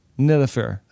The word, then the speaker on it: Nilüfer: Nilüfer.